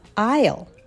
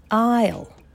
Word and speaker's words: aisle: 通路側、 aisle。この S を発音しない。 aisle。